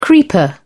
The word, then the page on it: creeper: British English